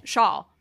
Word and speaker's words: Schaal: But anyways, they get visited by Rufus's daughter, played by Kristen Schaal. Is that right? Is that how you say her name? – Schaal.